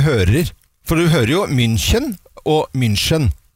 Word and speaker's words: München: Det jeg har hengt meg opp i, det Kim sin uttale på Bayern München. – Ja, du sier München.